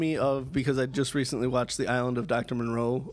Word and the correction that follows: Moreau: Moreau, whatever. We had this discussion, didn't we? – Yeah. And you were pronouncing it Monroe the whole time, and I didn't have the heart to stop you.